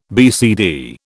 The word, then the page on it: BCD: British English